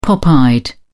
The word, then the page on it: pop eyed: British English